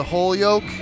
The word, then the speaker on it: Holyoke: It's actually pronounced the whole yoke, so there's no Y in it. – Really? – Even though there is a Y.